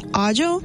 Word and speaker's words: aglio: And I've learned that garlic was written as aglio, A-G-L-I-O. So I was like, oh yeah, I'm looking for aglio. And they were looking at me like I was crazy. And I was like, aglio? It's pronounced agio.